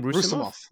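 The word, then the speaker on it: Roussimoff: All right, so Andre, Rusim, how would you say his name? – Rusimov. Rusimov. Rusimov, right? – Yeah.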